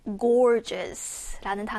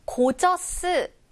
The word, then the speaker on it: gorgeous: 이번에는 gorgeous라는 단어네요. 고저스 아니고요. gorgeous. gorgeous. gorgeous.